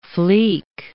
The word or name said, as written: flic